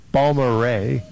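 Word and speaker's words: Balmorhea: I think they pronounce it Balmoray.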